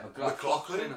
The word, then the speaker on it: McLaughlin: How do you say it? – McLaughlin? – McLaughlin, that's it. Like Craig McLaughlin, the singer.